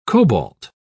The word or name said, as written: cobalt